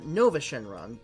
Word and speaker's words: Nuova Shenron: Um, he's uh, Nova Shenron. It's spelled like Nuova Shenron, but it's Nova Shenron, the invincible four star dragon.